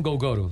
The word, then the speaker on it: Gogoro: Una cosa importante, Terpel anuncia una alianza con, no sé cómo decirlo, Gogoro o Gogo. – Sí, sabes que tienes razón. – Gogoro. – Gogoro. No sé cómo se pronuncia exactamente. – En Europa le dicen Gogoro.